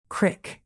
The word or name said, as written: crick